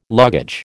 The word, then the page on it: luggage: American English